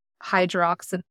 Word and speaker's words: hydroxyapatite: Yeah, and that something else is called hydroxyapatite. – We did Google that one time to make sure we were pronouncing it correctly. – And we do. We've got it. Yeah. Hydroxyapatite. Anyways,